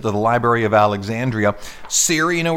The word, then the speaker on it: Siri: No, It's from SRI, International Artificial Intelligence Center, the SRI. SRI means Siri. That's where it came from. If you were to pronounce it, that's how you would do it.